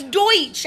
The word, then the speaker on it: Deutsch: It's Deutsch. That's how you say it in German. – But D-U-T-C-H.